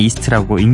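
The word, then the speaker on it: E^ST: 그래서 E 웃음 표시 하나, 그다음에 ST 이렇게 해서 이스트입니다. 읽을 때는 그냥 이스트라고 읽는데요.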